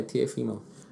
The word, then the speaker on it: Teofimo: So the second 21-year-old, that's also a rising talent, Teofimo. Is that how you say it? Teofimo? – Yeah, Teofimo.